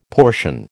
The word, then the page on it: portion: American English